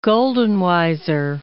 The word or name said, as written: Goldenweiser